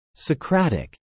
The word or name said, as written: Socratic